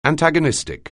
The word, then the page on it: antagonistic: anglais